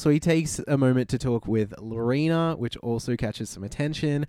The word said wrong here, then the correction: Lenora: Who, sorry? – He, did I say it wrong? – Lenora. – Ah, my mistake.